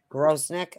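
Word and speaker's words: Grosenick: Booth, I'm sorry, it's Grosnick. Is that how you say it? Grosnick, okay. – Yep.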